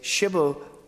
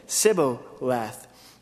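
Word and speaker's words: Shibboleth (שבולת): If he said, 'No,' then they would say to him, they say, 'Shibboleth,' and he would say, 'Sibboleth,' for he could not pronounce it right."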